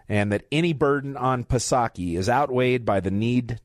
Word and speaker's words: Psaki: We will also be talking about something I am tremendously thankful for, and that is that Jen Psaki, and yes, I know the P is silent, but I just love Psaki, so I can't do it.